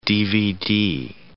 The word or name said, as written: DVD